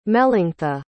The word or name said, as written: melanctha